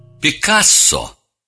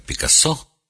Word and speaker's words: Пикассо: Пикассо, но иногда у нас произносят его фамилию как Пикассо, самый плодовитый из всех художников.